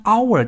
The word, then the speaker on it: hour: 比如一个小时用英文表达是 an hour，虽然 hour 这个单词是以 h 开头的，但因为 h 是一个不发音的哑音字母，所以这个单词的第一个发声因素是 ow，是元音因素，所以我们要用 m。